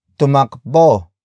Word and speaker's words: tumakbo: Tumakbo ka na. Tumakbo ka na. The first word is tumakbo, which is spelled as T-U-M-A-K-B-O.